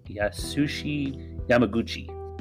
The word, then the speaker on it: Yasushi Yamaguchi: And his design was handled by a level artist named Yasushi Yamaguchi.